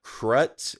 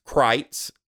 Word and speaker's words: Kreuz: Now, it's spelled like Krutz. It's spelled K R E U Z, but it's German, so it's pronounced Kreitz.